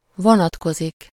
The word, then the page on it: renvoyer: [ʁɑ̃.vwa.je]; France